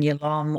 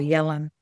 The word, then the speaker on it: Yalom: I guess what I would really like to say is, how influenced I've been by Irvin Yalom, or Yalom is how the Americans say his surname, how influential he's been on me as a therapist and group therapist.